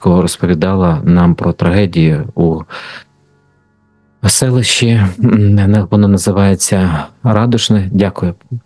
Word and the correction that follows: Радушне: Радушне. – Радушне, так, дякую за те, що ви виправили наголос, так, бо я у вас не живу, але разом з тим мені завжди приємно, коли колеги підтримують в цьому плані.